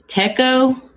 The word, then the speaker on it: Tekoh: You know, I think his name is pronounced Teko, T-E-K. O-H is Vega versus Teko.